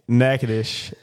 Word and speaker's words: Natchitoches: It's pronounced Natchitoches. – That's not how you pronounce that word. – Dude, you didn't even pronounce the first T.